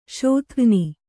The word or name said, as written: ಶೋಥಘ್ನಿ